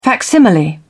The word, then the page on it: facsimile: British English